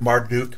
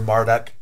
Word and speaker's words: Marduk: He's not saying, go and worship Marduk or Marduk, depending on how you want to pronounce his name.